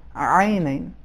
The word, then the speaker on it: عینین: ایک یہ کہ حرف عین اس کا عربی تلفظ اردو سے تھوڑا سا مختلف ہے, حرف عین میں ایک خاص غلظت ہے کہ جو اردو میں نہیں ہے, عینین.